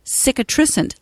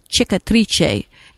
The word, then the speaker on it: cicatrice: It's antiseptic, antispasmodic, skin healing, wound healing, and it is cicatricent.